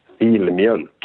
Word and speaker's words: Filmjölk: It's F-I-L-M-J, and then there's the umlaut on the O-L-K. – Filmjölk.